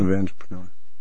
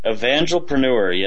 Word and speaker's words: Evangelpreneur: Is that how to pronounce it? – Evangelpreneur. – Evangelpreneur, yes, sir.